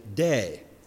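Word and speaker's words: day (δεῖ): It's simply three letters, and to pronounce it in English is to simply say day. So the Greek word pronounced day is a very short but a very powerful and a very important word.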